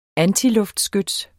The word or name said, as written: antiluftskyts